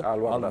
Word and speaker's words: Al-Wahda: Adrian Mutu antrenează pe Al-Wahda, așa se pronunță? Al-Wahda. Al-Wahda.